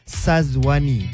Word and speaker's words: Sazwani: I'm pretty much sure I say my name right, Sazwani, but orang mesti akan tulis Shazwani, S-Y. Sama juga bila baca, it is clearly written as Sazwani, tapi still people akan sebut Shazwani.